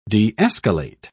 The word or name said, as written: de-escalate